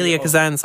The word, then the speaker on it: Elia Kazan: The director Isela Kazan first toyed with the idea Elia Kazan, sorry. – Yep, Kazan, sorry. I tried pronouncing.